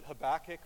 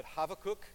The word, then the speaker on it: Habakkuk: By the way, generally in English, in American English, we pronounce it Habakkuk, but Habakkuk is also common. So if you hear somebody say it a little bit differently, that's okay.